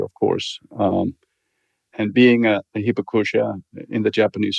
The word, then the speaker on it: Hibakusha: Hibakusha, am I pronouncing that correctly? – Yes. – Okay. What is Hibakusha? – Hibakusha is a survivor of the atomic bomb.